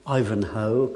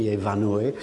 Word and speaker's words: Ivanhoe: On peut noter aussi le titre, qui vient de Scott, comme vous le savez sans doute, Ivanhoe, que les Français ont tendance à prononcer Ivanhoe, de 1819, Ivanhoe déguisé vint une série de chevaliers normands, il est saxon, dans un tournoi.